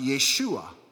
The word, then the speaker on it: Yeshua: And in Aramaic, it's pronounced Yeshua.